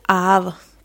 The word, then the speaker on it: Haab: que es el Aab, que se escribe H-A-A-B larga, que es un calendario que también tenía 365 días, pero que estaba dividido en 18 meses de 20 días cada uno.